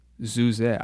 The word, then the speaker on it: Zuze: This is from Canadian jazz group Zuze. I believe that is how it is pronounced. It's spelled Z-U-Z-E.